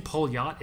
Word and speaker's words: Poljot: So I'm saying Polyot, it's spelled Poljot, but it's Polyot, so it's P-O-L-J-O-T.